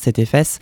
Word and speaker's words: CTEFS: Du coup, le CTFS, c'est le collectif trans en Finistère Sud, CTEFS, donc l'acronyme, ça se prononce CTFS.